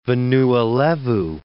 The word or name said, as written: Vanua Levu